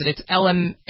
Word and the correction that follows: imatinib: It's the worst name ever. But it's a really important drug, and it's just under $9,700 a month. – Imatinib.